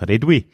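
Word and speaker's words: Réduit: Nämlich war das die sogenannte Redouille-Strategie. Ich glaube, mein bester Versuch, das Französisch auszusprechen. Im Schweizerdeutschen wird es aber noch komischer ausgesprochen. Das Redouille.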